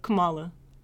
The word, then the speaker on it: Kamala: She says, Sojourner, Harriet, Shirley, Barbara, Kamala, we're gonna win. – You still don't pronounce her name right. Kamala. – Kamala, Kamala. – It makes a difference.